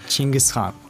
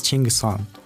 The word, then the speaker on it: Chinggis Khan: Chinggis Khan. Chinggis Khan. – Right, because he's pronounced differently over there, right? – Yeah, in my country it's Chinggis Khan, and the same like all the Central Asian countries, the same pronunciation like Chinggis Khan.